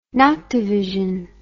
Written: noctovision

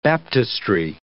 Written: baptistery

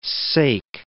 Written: sake

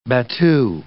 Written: battue

